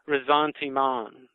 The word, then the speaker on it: ressentiment: It's Resentment by Max Scheler, S-C-H-E-L-E-R. Resentment is spelled R-E-S-S-E-N-T-I-M-E-N-T. And it's a French word, and it's actually pronounced Resentiment, but I'm just going to say resentment.